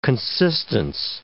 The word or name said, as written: consistence